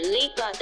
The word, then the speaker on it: LEEBADA: 音乐的部分要推荐的是之前在聊天室就有提过的歌手，韩国的以巴大，英文发音是 Liba 的，这首歌名叫 Drop。